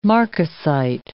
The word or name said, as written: marcasite